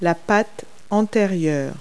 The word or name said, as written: forepaw